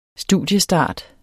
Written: studiestart

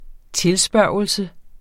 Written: tilspørgelse

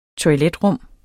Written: toiletrum

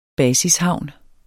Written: basishavn

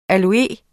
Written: aloe

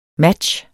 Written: match

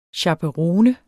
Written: chaperone

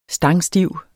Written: stangstiv

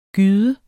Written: gyde

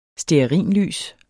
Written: stearinlys

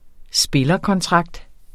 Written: spillerkontrakt

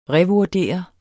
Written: revurdere

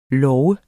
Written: lov

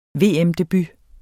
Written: VM-debut

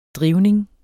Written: drivning

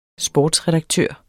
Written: sportsredaktør

